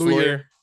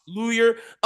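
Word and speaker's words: Loyer: The next highest person was their guard, Fletcher Lawyer, however you pronounce it, L-O-R-Y-E-R. Yeah. Well, I think I'm pretty sure that's Lawyer. Lawyer.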